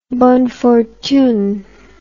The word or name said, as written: bonne fortune